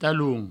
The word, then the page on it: talon: provençal; taloun